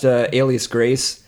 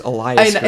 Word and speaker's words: Alias Grace: I always thought it was Elias Grace. – And Cole was calling it the entire time Elias Grace, and I was like, God, I guess I don't know how words work.